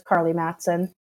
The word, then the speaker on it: Karlie Matson: They're both just my name, which is spelled uniquely, but it's Karlie Matson.